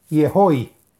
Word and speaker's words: Yehoi: Está recomendada por la página de Facebook Kabbalah y Torah en Expansión la cual trae un nombre sagrado para meditar y son las letras Yud Hei Vav Yud y se pronuncia Yehoi.